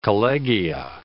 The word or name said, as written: collegia